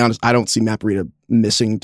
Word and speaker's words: Breida: Matt Breida, did we figure this one out? Breida, DK, which one is it? – Breida. – Okay. – Rhymes with cheetah is what I was told.